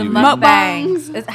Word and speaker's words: mukbang: Oh, the mukbangs. How do you say it? – Mukbang. It's bang, but...